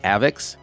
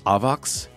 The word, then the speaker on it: AVAX: If I said Avex, they're pissed. But if I said Avox, they're okay.